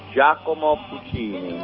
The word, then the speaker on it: Giacomo Puccini: Does that sound like the right way to say his name? – This is Nessun Dorma by Giacomo Puccini. – There you go.